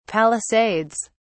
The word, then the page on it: palisades: British English